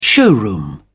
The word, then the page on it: showroom: British English